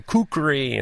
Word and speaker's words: kukri: And I just said, my old man used to call it a Gurker knife. And they're like, no, no, that's not the right cor- correct pronunciation. And, and, it's a it's a kukri.